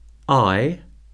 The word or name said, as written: eye